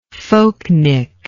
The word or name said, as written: folknik